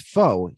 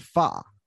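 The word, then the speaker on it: pho: By the way, Richard Lewis also mispronounces foe. He calls it fa when he's looking at the menu.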